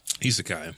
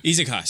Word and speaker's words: Isekai: Uh, how you pronounce it again? As- Asaki? Isekai. Isekai, sorry.